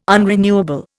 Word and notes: unrenewable: Indian

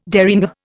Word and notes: derringer: Indian

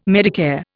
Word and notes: Medicare: Indian

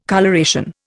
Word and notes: coloration: Indian

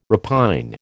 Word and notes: repine: American English

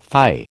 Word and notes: phi: American English

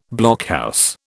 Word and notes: blockhouse: British English

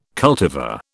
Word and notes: cultivar: British English

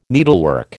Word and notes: needlework: American English

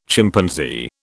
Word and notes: Chimpanzee: British English

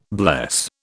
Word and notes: bless: British English

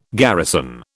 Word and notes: garrison: British English

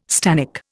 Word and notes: stannic: Indian

